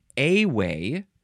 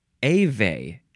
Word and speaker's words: Ewe: You, no, this is not how you, it's spelled E-U-E, E-W-E, you got it, it's a W, E-U-E would be different, but the E, the first E is capitalized, capital E-W-E, you can pronounce this A-way or A-ve, A-way or A-ve, noun from 1861, the plural is either a same word, ave or awe, or you can add an S.